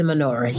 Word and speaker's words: menorah: But in the hotel in Greenbelt, they had before the transition, they had a menorah up there too. Did I say that right? – Uh-huh. No. No. Yes. Yes, it is a menorah.